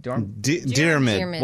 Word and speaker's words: Diarmid: Diarmid. – What is it? – Like Diarmid. – But it's spelled Diarmid. – Yeah, but it's like Pyramid.